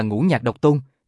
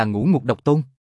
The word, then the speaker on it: Ngũ Nhạc Độc Tôn: Hoàng Phi Hổ nói, bốn chữ này các ngươi đọc là Ngũ Nhạc Độc Tôn, nhưng mà người thực sự hiểu rõ Thái Sơn, đều sẽ đọc là Ngũ Ngục Độc Tôn.